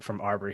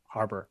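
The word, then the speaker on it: Harbour: And we get to see a little bit of comedic chops from Arbor here. Harbor.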